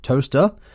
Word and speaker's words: Towcester: Towcester is pronounced toaster, and Alnwick is pronounced Annick.